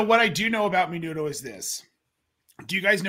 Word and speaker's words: menudo: Can someone pronounce this word before I butcher it? – Menudo. – Say it again, Kyle. – I believe it's menudo.